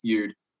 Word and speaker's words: Bude: It's the only four-letter word that everyone gets wrong. it's pronounced Bude.